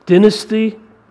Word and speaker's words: dynasty: He's lost his dynasty, which is the British pronunciation of that word.